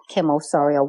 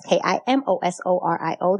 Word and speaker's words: Kim Osorio: on my Instagram channel at Kim Osorio one. That's K I M O S O R I O, the number one.